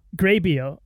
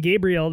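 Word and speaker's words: Grabeel: Grabiel, which I did not know, because I've been saying Gabriel this whole time.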